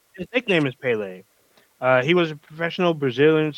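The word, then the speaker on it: Pelé: It's Pele.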